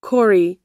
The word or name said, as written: kore